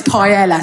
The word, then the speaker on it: paella: It's chorizo and it's paella, so I fucking get your pronunciation right, you bell end.